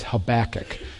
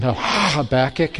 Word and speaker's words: Habakkuk: His name is Habakkuk. You kind of have to make a loogie when you say his name. Habakkuk, it's the correct Hebrew way of doing it.